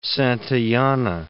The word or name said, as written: Santayana